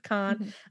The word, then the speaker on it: Cannes: So in 1994, there were two baby girls born in, is it pronounced Cannes, France? Yeah. I was like, the place where the film festival is, Cannes, France.